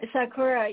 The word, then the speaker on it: Sakura: Sakura, yeah, that's right.